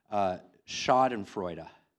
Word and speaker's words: Schadenfreude: And in this video, I learned a word, and the word is this, and it's hard to pronounce, it's a German word, Schadenfreude.